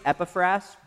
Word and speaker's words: Epaphras: You can pronounce his name a different ways, but that seems to be the most consistent, Epaphras.